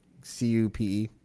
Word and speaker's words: CUPE: Um, how do you say that? CUPE, C U P E. Yeah, CUPE.